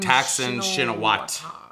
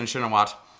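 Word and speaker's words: Shinawatra: Thaksin Shinawat. – You don't pronounce the RA. I found that out. Yeah. Thaksin Shinawat.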